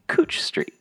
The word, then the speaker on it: Couch Street: As a lifelong Portlander, it's pronounced Couch Street.